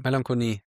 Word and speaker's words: Melancholie: Wow, ich glaube, das habe ich mein Leben lang falsch ausgesprochen, das Wort. – Melancholie.